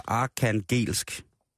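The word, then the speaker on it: Arkhangelsk: Ole Fredslund siger, det udtales altså arkangelsk arkangelsk. Ja.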